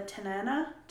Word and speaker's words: Tanana: People also saw him loitering around the Tanana, I believe that's how you say it. T-A-N-A-N-A. It's like banana with a T.